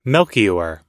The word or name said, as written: melchior